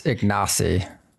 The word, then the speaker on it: Ignacy: Well, I'm pretty sure it's Ignacy. At least that's how they pronounce it on this podcast.